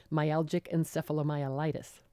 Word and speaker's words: myalgic encephalomyelitis: or if I pronounce this correctly, myalgic encephalomyelitis. – Well done.